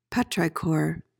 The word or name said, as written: petrichor